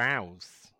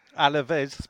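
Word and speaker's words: Alavés: Our brilliant goalkeeper, Roberto, made his debut for Alves. – Alves? – Alves, Eves, whatever they're called. – Alaves. – Spanish. Alaves. There you go.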